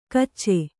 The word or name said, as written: ಕಿಚ